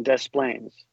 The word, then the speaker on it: Des Plaines: You pronounce the S's in Des Plaines.